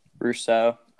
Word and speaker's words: Rousseau: But I think, yeah, I'm 7-5-2. Just that D line looks awesome. I mean, Roche or Gregory, how do you say his name? – Rousseau. Gregory Rousseau.